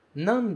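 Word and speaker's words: nan (何): Nan desu ka? Nan desu ka? Esse kanji que aparece se pronuncia nan.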